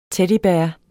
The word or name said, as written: teddybear